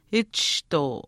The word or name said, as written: achishtuu